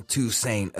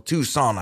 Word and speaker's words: Toussaint: I'm going to get back to Alan Toussaint as well, or Toussaint, I think is the way it's pronounced.